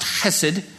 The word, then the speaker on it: hesed: It comes from a, a, Hebrew word. It's pronounced hesed.